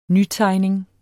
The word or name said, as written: nytegning